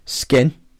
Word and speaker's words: Skyn: Skin. But it's spelled. – That sounds really weird. – S-K-Y-N.